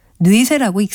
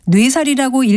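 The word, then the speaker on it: 뇌쇄: 또 여자가 그 미모로 남자를 매혹해 괴롭힌다는 뜻으로 말할 때는 매우, 몹시라는 의미로 쓰여서 뇌쇠라고 읽습니다. 뇌살이라고 읽기 쉽지만 이때는 뇌쇠가 올바른 발음입니다.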